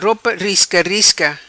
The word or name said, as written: Ropa Risca Risca